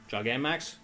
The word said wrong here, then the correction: Gigantamax: Fine. But that's a chonky Pikachu. – Big chonky boy. – It's like OG Pikachu. – Yeah, Gigantamax. – Sorry, Gigantamax. That's too many syllables.